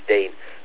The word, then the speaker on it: Dean: It's Dean.